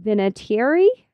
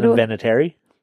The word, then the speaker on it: Vinatieri: Endorsed by a whole bunch of people, including New England Patriot Adam Vinatieri. Am I saying that right, Dave? – No. – Vinatieri. There you go.